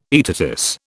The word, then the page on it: aetatis: British English